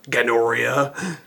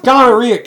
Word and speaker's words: gonorrhea: You ready for some gonorrhea? – It's pronounced gonorrhea, Captain.